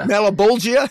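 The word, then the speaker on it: Malebolgia: He's got no powers because his powers were taken away from him by Malabrogia. Did I say that right? – Malabulgia, yeah.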